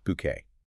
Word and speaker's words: Bucket: You know, the story of the eccentric, snobbish, middle-class social climber, Hyacinth Bucket, who, and we always have to remember this, insists her name is pronounced Bouquet.